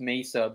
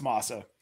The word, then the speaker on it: masa: I pronounced mesa, but I must be wrong on that one too. – You are. You are. It's masa.